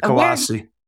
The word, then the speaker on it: Colossae: Before we get going on that more, where is the city of Colossae? I'm sorry. – Colossae. I pronounce it Colossae.